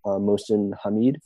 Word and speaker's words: Mohsin Hamid: And I don't want to mispronounce the author's name, but I believe you'd say it, Mohsin Hamid.